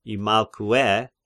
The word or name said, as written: Imalkue